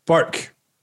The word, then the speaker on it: Bourke: B-O-U-R-K-E. How do you say it? – B-O-U... Burke. Burke. Okay. Burke.